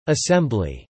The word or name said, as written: assembly